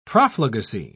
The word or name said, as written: profligacy